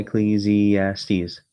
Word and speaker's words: Ecclesiastes: Ecclesiastes. And actually, you can sound it out. It's all phonetic.